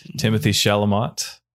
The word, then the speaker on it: Timothée Chalamet: Timothée Chalamet.